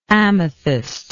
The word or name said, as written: amethystlike